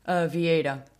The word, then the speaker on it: Vieira: How do you pronounce it? – Vieira. – Vieira. Oh. – It's really weird. Portuguese language is really weird, don't worry.